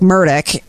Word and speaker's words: Murdaugh: I've been watching the Murtalk, It's Alex Murdaugh, but it's pronounced Alec Murdoch, and that's because there's a lot of southernness going on in this trial because it's in South Carolina.